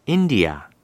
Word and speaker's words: India: India, India 라고 합니다. 여기는 첫 번째 in 부분을 강하게 발음하면 돼요.